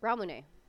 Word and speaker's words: Ramune: How's that pronounced again? – Ramune. – Ramune. Okay. I was thinking ramenade.